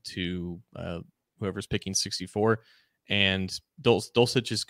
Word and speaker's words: Dulcich: I think Dulcich, yeah, you pronounced it correctly.